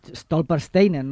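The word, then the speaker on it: Stolpersteine: No hem citat el tema de les pedres Stolpersteinen, no sé, Sònia, si ho pronuncio bé. – Sí.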